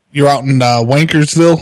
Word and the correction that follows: Walkersville: Walkersville, yes. – Walkersville, okay. I mispronounced it.